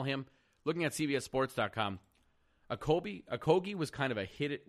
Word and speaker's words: Okogie: They end up taking Josh Okogie. Now, again, that is how you pronounce his name. It is Josh Okogie.